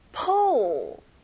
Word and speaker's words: pull: 당기다. Pull. 뒤에 어 소리 잊지 마세요.